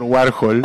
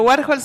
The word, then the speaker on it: Warhol: que me digan por Warhol. A ver si abajo. – Nuestro Warhol se escribe UAJOL. Warhol.